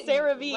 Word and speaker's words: CeraVe: Why are you adding extra syllables? It's not like French. Cerave.